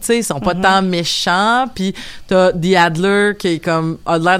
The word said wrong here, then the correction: Handler: d'avoir quelque chose The Adler ou The Handler ça s'écrit avec un N.